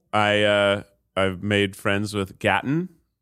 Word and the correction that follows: Gaten: Gaten Matarazzo? – Yeah.